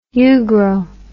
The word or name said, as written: ugro